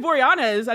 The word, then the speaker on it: Boreanaz: David Boreanaz, I don't know. – Oh, God damn it. Am I pronouncing his name wrong? – Yeah.